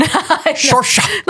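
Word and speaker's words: Saoirse: I can't pronounce it. It's an Irish name. I want to say it's like Shorsha something. – Shorsha. – Look, this is that's how it's spelled, but it's not pronounced the way it looks.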